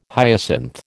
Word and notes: hyacinth: American English

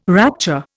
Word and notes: rapture: Indian

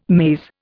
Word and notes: maze: Indian

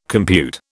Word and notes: compute: British English

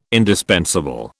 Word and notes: indispensable: British English

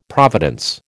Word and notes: providence: American English

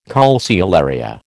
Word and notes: calceolaria: American English

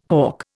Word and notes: hough: Indian

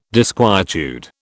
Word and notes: disquietude: British English

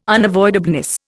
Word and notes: unavoidableness: Indian

